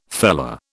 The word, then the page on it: fellah: British English